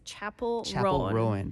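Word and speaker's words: Chappell Roan: Yes, I looked on social media 네. to listen to the singer's 네. pronunciation, mm. and it's Chapel Rowan.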